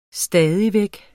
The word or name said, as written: stadig